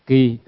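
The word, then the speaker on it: ki (木): Ki là mộc, viết chữ Hán là mộc nhưng đọc là ki.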